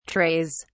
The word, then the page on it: trays: British English